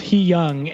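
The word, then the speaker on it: hyung: Okay, apparently it's pronounced He Young, and it means older brother.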